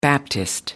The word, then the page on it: baptist: American English